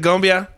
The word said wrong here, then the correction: cumbia: I'm asking you, did I say it right? – I'm sorry. – It's cumbia.